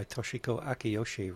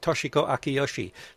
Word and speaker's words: Toshiko Akiyoshi: There aren't any, like in English, you have accents, right? The accent, the stress, a louder syllable, right? They don't have that in Japanese. It's all the same. So I don't know how to do that, but Toshiko Akiyoshi, something like that.